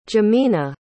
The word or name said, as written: jimena